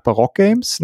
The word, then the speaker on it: Baroque Games: Ähm, gemeinsam habt ihr den Verlag Barock Games, ne? das spricht man so korrekt aus, oder? – Genau.